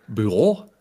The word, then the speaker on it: Bureau: Und dann siehst du da irgendeinen Rainer, 45, der sagt, Collector Büro. Dann sagst du, nee, sprich einfach Büro aus, dann passt das.